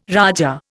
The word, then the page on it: raja: Indian